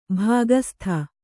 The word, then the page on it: ಭಾಗಸ್ಥ: bhāgastha